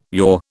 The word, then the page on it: yaw: British English